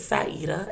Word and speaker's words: Sieda: My first name is Sieda, S I E D A.